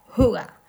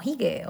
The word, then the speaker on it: hygge: Hace unos años se puso de moda el término danés Huga, Se pronuncia así de feo, Huga, pero se escribe con H, Y, G, G, E, algo que leeríamos como hige o haige.